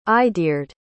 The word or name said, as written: eideard